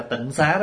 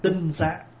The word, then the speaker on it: tinh xá: Bây giờ mình đọc trại là tịnh xá đó, chứ đúng cái chữ đã đọc là tinh xá.